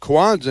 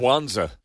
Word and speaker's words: Kwanzaa: That's because the reason she mispronounced Kwanzaa and called it what'd you call it? – Schwanza.